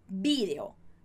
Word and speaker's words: vídeo: E daí a gente tem vídeo, a palavrinha vídeo no espanhol da Espanha, você vai ver assim, com acento igualzinho no português e a pronúncia com B bem marcado, mas a ênfase da palavra vai estar no bi. Então, vídeo, vídeo, ok?